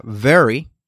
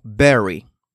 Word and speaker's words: very: Por ejemplo, la palabra very, very, que es muy, um, puede sonar como berry, berry, que es vaya.